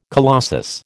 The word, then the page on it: colossus: American English